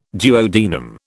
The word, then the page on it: duodenum: British English